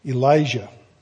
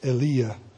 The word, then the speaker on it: Elijah: The prophet Elijah, probably pronounced Elia, but I'm calling him Elijah today.